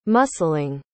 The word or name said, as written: muscling